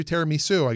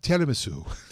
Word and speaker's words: tiramisu: And we were both wrong. It's tiramisu.